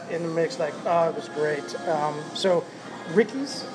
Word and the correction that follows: Risky's: is where we're at. If you guys are ever down, Risky's Barbecue. – Risky's, I said it wrong.